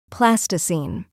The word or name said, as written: plasticine